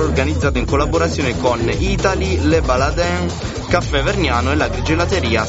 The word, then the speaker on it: Le Baladin: Hai letto Le Baladin perché si pronuncia così o perché sei il piemontese? Tu potremmo... – Ma l'ho letto alla francese, diciamo.